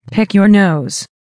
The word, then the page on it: pick your nose: American English